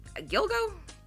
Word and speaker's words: Gilgo: episode about the Long Island serial killer, the Gilgo Beat. Is it Gilgo? Is that how you say it? Yeah.